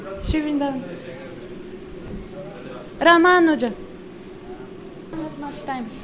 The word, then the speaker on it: Ramanuja: So we have Ramanuja. It's not Ramanuja. If you say Ramanuja, it means you make the second syllable short. So it's Ramanuja.